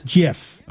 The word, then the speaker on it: GIF: I pronounce it the same way the guy who invented it pronounced it. – Which is? – GIF.